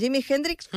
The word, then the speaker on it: Jimi Hendrix: En Jimi Hendrix, com es pronuncia? – Ho has fet molt bé.